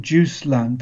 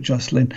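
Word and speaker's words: Josselin: The one in Jocelyn is a the French pronounce it Juice Land, but I call it Jocelyn.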